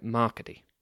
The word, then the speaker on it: Marcade: But the accent on the E of Marcade's name would make his name sound more like Marcady.